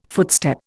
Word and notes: footstep: Indian

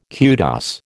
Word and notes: kudos: American English

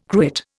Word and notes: cruet: Indian

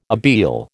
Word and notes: abele: American English

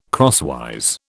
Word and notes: crosswise: British English

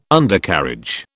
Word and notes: Undercarriage: British English

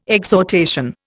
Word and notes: exhortation: Indian

